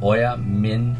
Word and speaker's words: Oyamin-Artok: I'll spell it for you. It's O-Y-A-M-I-N Oyamin-Artok. Oyamin-Artok. O-Y-A-M-I-N-A-R-T-O-K. An exceptionally great battle to be had with Artok. I don't think O-Y-A-M-I-N.